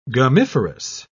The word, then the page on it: gummiferous: British English